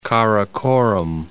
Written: Karakoram Pass

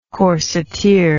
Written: corsetiere

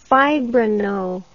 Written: fibrino

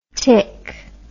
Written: Thich

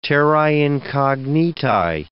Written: terrae incognitae

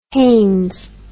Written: Hanes

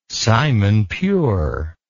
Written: simon pure

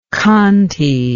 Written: khanty

